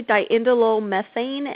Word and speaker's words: diindolylmethane: It goes by the initials D-I-M. – Yes. So it's actually diindolylmethane, and it's easier to just call it DIM.